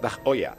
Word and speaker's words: Tag Heuer: Esto le doy las gracias a Tag Heuer. Me va a reñir Juanma porque dice, se pronuncia Tag Oya.